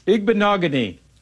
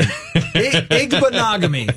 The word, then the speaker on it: Igbinoghene: With the 30th pick in the 2020 NFL draft, the Miami Dolphins select Noah Igbinoghene, defensive back, Auburn. – Well, celebrate for not having to say his name. Igbinoghene.